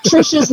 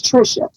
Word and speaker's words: Tricia: And she was like, but the way it's spelled, no, lady, first of all, Tricia's not a Norwegian name. Right. So you can't change it to try to make it pronounced as such. My name is Tricia. It's not Tricia.